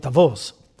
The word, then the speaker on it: Davos: I know they always call it, how do they pronounce it over here? Davos. You have to learn Swiss. It's a Swiss town. It's called Davos.